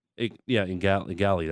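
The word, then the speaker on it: Igali: Daniel Ngali. Ngali. That's how you say his name.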